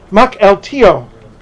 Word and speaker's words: Mukilteo: Orca Beverages in Mukilteo, let me see, I wrote down the pronunciation. Mukilteo. Washington State.